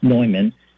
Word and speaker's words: Neumann: St. John Newman, pronounced Neumann, and St. Catherine Drexel.